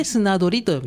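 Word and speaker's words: 漁り: はいはい、漢字で書きますと、山水に魚で、送り仮名がリーですね。これで砂取りと読みます。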